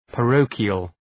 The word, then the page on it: parochial: {pə’rəʋkıəl}